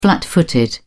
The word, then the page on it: flat footed: British English